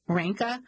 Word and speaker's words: Hranka: One of the most famous cases in immigration law, matter of, it's spelled H-R-A-N-K-A, Ranka was about a Canadian citizen deported from the U.S.